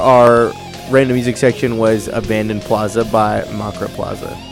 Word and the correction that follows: Macabre Plaza: I said that wrong. Macabre Plaza.